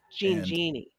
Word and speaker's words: Jean Genie: And always, you can use the hashtags Ask Alice, June Jams, Jean Ginny, and Nickey Notes. – Jean Genie.